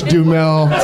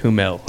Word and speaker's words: Duhamel: I love that David Cross even made a Josh Duhamel reference. – Well, if you rewind it, you'll see that I mispronounced it and said Humel.